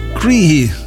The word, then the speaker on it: Croithe: Schrijft C-R-O-I-T-H-E. En dat moet je dan uitspreken als Cree of zoiets. Het is Iers.